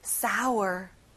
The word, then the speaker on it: sour: 여러분, 아우 할 때 입을 크게 벌려보세요. sour.